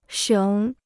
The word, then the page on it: 省: shěng